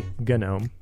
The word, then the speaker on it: GNOME: GNOME also takes a hard G, GNOME.